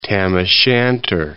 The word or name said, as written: Tam o' Shanter